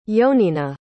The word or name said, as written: yoninah